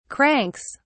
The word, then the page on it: cranks: British English